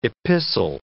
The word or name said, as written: epistle